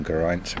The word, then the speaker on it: Geraint: I love the name Geraint because famously it's the only name that rhymes with pint.